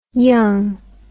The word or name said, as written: yonge